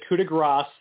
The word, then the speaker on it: coup de grace: And last praise for Jimmy Smith, he actually said coup de grace, and he overpronounced the S so that Corey stopped giving him a hard time. – I know. I was waiting for that to see if he would defy Corey Graves, because that's one of Corey Graves' things he's really proud of, is he knows that should be grace.